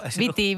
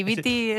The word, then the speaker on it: Beat It: no Viti, Beat It.